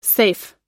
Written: seif

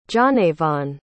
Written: johnavon

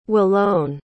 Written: Wilone

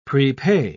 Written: prepay

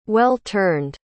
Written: well-turned

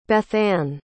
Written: Bethann

